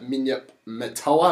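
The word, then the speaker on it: Minyip Murtoa: He played at Hastings, and he's also played at Minyip Matoa. Have I said that right, KP? – Yeah, that's good. – And he's also played in a premiership alongside Central, alongside Daniel Corp for Central...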